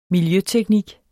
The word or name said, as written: miljøteknik